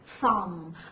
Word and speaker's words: thumb: 啊，首先是 th 的发音，那么我们注意一下舌头放在齿中间，对不对？ thumb 啊，注意这个 b 不发音。